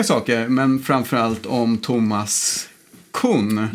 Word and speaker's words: Kuhn: Han är Kuhn. – Vetenskapsteoretiken. – Och det är ganska många som säger Kuhn. – Jag vet inte riktigt hur man ska uttala det. K-U-H-N. – Kuhn. – K-U-H-N.